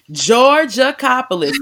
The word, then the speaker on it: Georgalopoulos: Did I say it right, Ashley? – Georgalopoulos. – Georgalopoulos. They already know me. I'm famous for like messing up names.